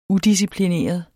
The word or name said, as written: udisciplineret